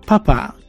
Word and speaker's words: papà: Papà, con l'accento sulla A, sulla lettera A, è un'altra parola che usiamo per chiamare il nostro padre.